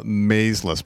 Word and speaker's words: Maysles: And this, like, by the way, this movie is a documentary put out by the Maysles brothers. Mm-hmm. Is that how you say it? – Yeah. Maysles brothers. – Maysles, okay.